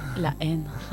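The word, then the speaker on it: La Haine: A ver, ¿cómo se dice? – La En. – Muy bien.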